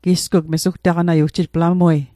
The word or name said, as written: mesugtaqanat